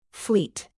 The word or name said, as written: fleet